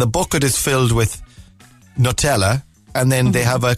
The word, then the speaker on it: Nutella: Nutella, the ultimate comfort food, or Nutella, as it's supposed to be. Yeah, that's the actual pronunciation of Nutella.